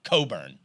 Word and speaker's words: Cockburn: And his last year, he changed the pronunciation to Coburn.